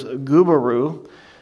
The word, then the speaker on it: Gubaru: His real name was Gubaru. So to make this real to us here in New England, think Subaru with a G. That's the way it's spelled, Gubaru.